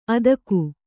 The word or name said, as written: ಆದೀಕ